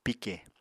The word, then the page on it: pike: Apatani